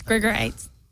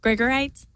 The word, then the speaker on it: Grigorites: Nobody pronounces it correctly. It's Grigorites. – I'm sorry? – Grigorites. – Grigorites. Mm-hmm. Hannah Grigorites. – Mm-hmm. It's like a fine stone.